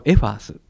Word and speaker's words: FAS: はい、こちらは藤沢の F ですね、アートの A、スペースの S の頭文字を取って、まあ、 FAS と書くんですけれども、まあ、こちらをエファースと読みます。